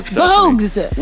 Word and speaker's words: Bogues: I mean, we're still trying to get Muggsy Bowes. – I know. I finally learned how to say his name. – Muggsy Bowes. – Oh, come on. – Bogues.